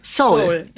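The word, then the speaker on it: Seoul (서울): Es gibt im Koreanischen zwei O's. Das eine ist O und das andere eher so ein O. Und das Seoul O ist das O.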